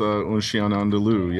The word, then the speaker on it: Un Chien Andalou: So this is Dalian Bunuel's Un Chien Andalou.